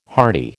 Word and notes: hearty: American English

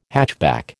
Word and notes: hatchback: American English

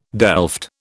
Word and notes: delft: British English

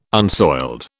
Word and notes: unsoiled: British English

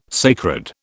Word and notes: sacred: British English

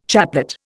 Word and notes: chaplet: Indian

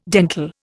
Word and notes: dentil: Indian

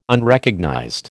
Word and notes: Unrecognized: American English

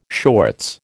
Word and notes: shorts: American English

